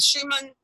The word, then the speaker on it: Schumann: The Schumann, am I pronouncing that right? Yeah, yeah, no, the Schumann resonance.